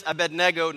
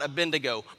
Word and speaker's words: Abednego: And by the way, it is Abednego, not Abednego. My gosh, okay? Please say it correctly.